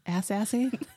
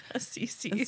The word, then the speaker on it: Assisi: Ass-assy? – Ass-assy.